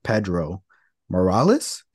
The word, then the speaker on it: Pedro Morales: a little over 400 days to beat Pedro Morales.